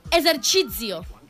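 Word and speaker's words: esercizio: Perquè la pronunciació és, escolteu, exercicio. Bueno, exercicio.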